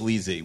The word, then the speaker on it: Lisi: She's credited as Lisi, but it's pronounced in the episode as Lizzie with a Z.